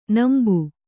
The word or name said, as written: ನಂಬಿ